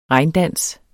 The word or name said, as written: regndans